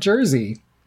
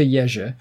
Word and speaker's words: Jerzy: It's not Jersey. – We never said Jersey. We knew it wasn't Jersey, but we were definitely not close to Jerzy.